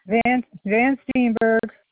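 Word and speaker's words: Van Steenburg: Van Steenberg. – Okay, can you spell that for me, please? I'm sorry. – Capital V-A-N, capital S-T-E-E-N-B-U-R-G.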